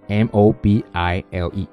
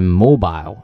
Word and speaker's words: mobile: 接下来一个词叫 mobile，或者读成 mobile 啊，它的拼写是 m o b i l e 啊，意思是移动的啊。英国人读成 mobile 啊，美国人读成 mobile 啊。